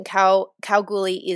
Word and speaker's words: Kalgoorlie: And just on the pronunciation, Kalgoorlie is a local Woongatha word from Galgala, which means is like a silky pear that's like native to this area.